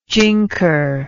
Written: jinker

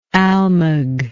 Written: almug

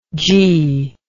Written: jee

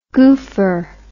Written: goopher

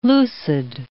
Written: lucid

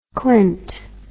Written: klint

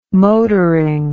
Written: motoring

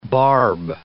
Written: barb

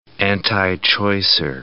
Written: antichoicer

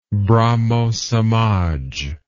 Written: brahmosamaj

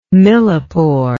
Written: millepore

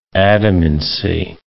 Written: adamancy

